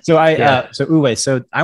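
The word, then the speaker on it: wu wei: Uwe? – Uwe.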